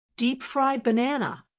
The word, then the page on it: deep fried banana: American English